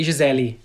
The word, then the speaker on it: Gisele: Like Gisele, yeah, that's how you say it. We say Gisele, Gisele Bündchen.